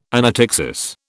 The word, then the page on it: anaptyxis: British English